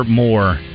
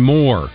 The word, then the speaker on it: Moore: He said Moore and Moore. Now, how do you pronounce M-O-O-R-E? – Moore. – Moore. Not Moore. – But he used both of those. It's not Moore, it's Moore, like M-O-R-E.